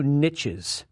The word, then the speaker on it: niche: niches. Yes, it's pronounced niche, my millennial friends out there.